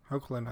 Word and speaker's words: Hoechlin: Hoechlin, I think, is how he pronounces it.